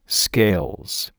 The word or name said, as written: scales